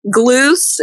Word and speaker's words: Gluse: Gluse. It's spelled G-L-U-S-E.